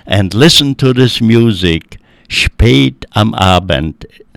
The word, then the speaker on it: spät am Abend: Well, number four is spät am Abend.